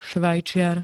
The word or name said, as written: svajciar